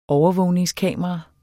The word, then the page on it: overvågningskamera: dansk